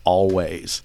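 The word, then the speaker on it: Alvvays: Always spelled A-L-W-A-Y-S. Alves. There I go, spelling again. It looks like it should be pronounced Alves, but it's just a stylistic, let's just jam two V's together and pronounce it like it's a W.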